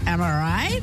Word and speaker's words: amirite: slang, of course, for am I right? It's spelled A-M-I-R-I-T-E. – Yeah. – Am I right? – I love it.